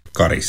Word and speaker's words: Karis: Hur man riktigt ska uttala namnet på, ja, den där orten som har en viktig järnvägsstation, handbollslaget BK46 och som numera hör till Raseborg. Karis, eller Karis.